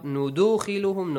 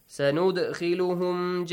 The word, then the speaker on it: سَنُدْخِلُهُمْ: سَنُدْخِلُهُمْ Not نُدُخِلُهُمْ, no. نُدَخِلُهُمْ, no. نُد. Just an echoing sound. سَنُدْخِلُهُمْ جَنَّات Noon mushaddad will be pronounced with an extension and a nasal sound.